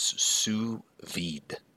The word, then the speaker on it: sous vide: That term is sous vide.